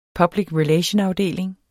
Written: public relation-afdeling

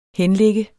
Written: henligge

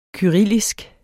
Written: kyrillisk

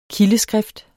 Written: kildeskrift

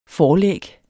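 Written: forlæg

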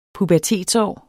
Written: pubertetsår

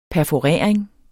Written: perforering

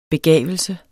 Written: begavelse

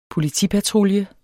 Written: POLITIPATRULJE

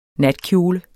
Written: NATKJOLEN

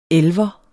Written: elver

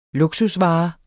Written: luksusvare

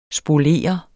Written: spolere

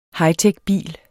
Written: hightechbil

